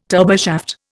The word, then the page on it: turboshaft: Indian